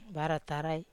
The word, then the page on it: vara tarai: Wamesa